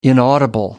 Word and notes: inaudible: American English

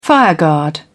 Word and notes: fireguard: British English

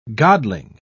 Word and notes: godling: British English

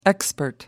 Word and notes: expert: American English

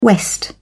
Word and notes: west: British English

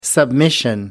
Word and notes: submission: American English

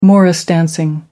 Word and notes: morris dancing: American English

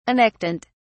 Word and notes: annectent: British English

